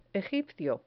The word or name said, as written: egipcio